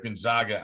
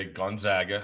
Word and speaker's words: Gonzaga: Gonzaga on, or I should say Gonzaga, how the right way to say it.